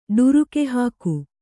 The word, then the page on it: ಢರಕಿಹಾಕು: ḍharaki hāku